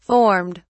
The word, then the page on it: formed: British English